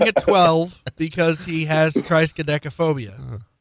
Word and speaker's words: triskaidekaphobia: Spell trichodescophobia. – What? – Sorry, I mispronounced it. Triskaidekaphobia.